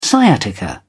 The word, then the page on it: sciatica: British English